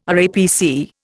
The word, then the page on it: RAPC: Indian